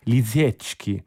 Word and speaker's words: Lisiecki: Bene, trova della Deutsche Grammophon, lui pubblica per Deutsche Grammophon, credo che la pronuncia esatta sia Lisiecki.